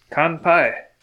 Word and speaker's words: Kanpai: Lesson of the week is Kanpai. It means cheers. And this is very fitting for our episode number one because we like to drink and here we are. – Cheers. – Kanpai. – Kanpai. – Kanpai. – And for listeners at home, it's spelt K-A-N-P-A-I.